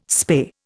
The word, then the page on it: spay: Indian